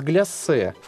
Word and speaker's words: гляссе: В отличие от гляссе, в котором все наоборот, ударение на втором слоге и одна буква «С».